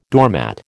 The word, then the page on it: doormat: American English